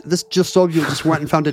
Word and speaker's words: Sobule: If you tax the rich, you don't get cool tribute albums with Jill Sobule.